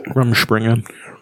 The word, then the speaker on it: Rumspringa: went on Rumspringa. However you pronounce it. – Rumspringa.